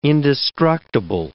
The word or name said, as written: indestructible